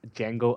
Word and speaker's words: Django: And the other film that's fully West Screen is Quentin Tarantino's Django Unchained. The D is silent, apparently.